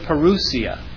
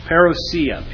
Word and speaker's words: parousia: The word parousia, or parousia. People pronounce it differently.